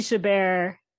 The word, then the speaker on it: Chabert: And it's strange too, because they've taken like Lacey Chabert, or I always say her name, like Cabernet, whatever, Lacey.